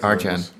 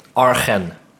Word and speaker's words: Arjan: Hoe spreek je het uit? – Arjan. – Ja, ik moet dan Arjan zeggen, maar dan krijg je dus... – AR. – Dan krijg je dus Argen. Dus A-R-G.